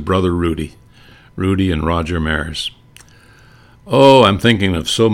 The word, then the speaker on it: Maris: Played for Sacred Heart Parochial School in Fargo, and it was always M A R A S. And he said, "Yeah, I'm, that's our family name, and it's pronounced Maris." But he said, "I got so tired of people mispronouncing it, Maras, that I changed the spelling on my own."